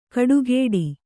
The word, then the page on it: ಕಡುಗೇಡಿ: kaḍugēḍi